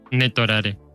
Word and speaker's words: Netorare: No sé, ¿cómo dijiste que se pronunciaba? ¿Netorare? – Netorare. – Netorare. – Ah, no sé, yo lo pronuncio así, no sé. – Sí, es que tú lo pronuncias más japonés que yo. Yo lo pronuncio muy, muy latino, güey.